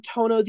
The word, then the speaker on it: Toño: I am Toño The Rapper. That's T-O-N-Y-O, but the tilde is silent, so it's just Toño when you say it out loud.